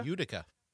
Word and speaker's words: Utica: Am I saying that right? Utica? – Utica. – Yeah.